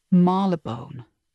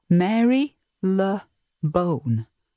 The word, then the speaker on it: Marylebone: And finally, how about Marylebone? So spelt Marylebone. It looks like Mary Le Bone, but it's pronounced Marylebone.